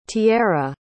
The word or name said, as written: tiera